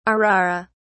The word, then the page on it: arara: British English